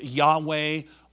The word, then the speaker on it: Yahweh: And most scholars now believe that it's supposed to be pronounced Yahweh or Yahweh, but it's the proper name of God.